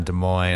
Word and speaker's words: Des Moines: KFMG night listeners, with Iowa Basement Tapes at the KFMG studios in Mainframe, downtown Des Moines. I said it correctly.